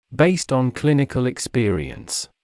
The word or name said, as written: based on clinical experience